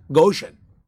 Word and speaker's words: Goshen: Goshen.